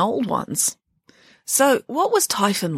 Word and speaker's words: Typhon: And this Titan was called Typhon. What, like typhoon? asked Michael. Yes, said Nanny Piggins, except it's spelled with just one O.